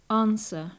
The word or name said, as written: answer